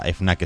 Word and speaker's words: Fnac: F.N.A.C. Anda, hijo. – Pues se dirá con acento francés, ¿no? Fnac. – Según el periódico Le Figaro, que para quien no lo sepa, FNAC es francesa.